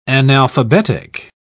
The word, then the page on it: analphabetic: British English